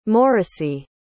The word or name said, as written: morrissey